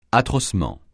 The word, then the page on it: atrocement: francais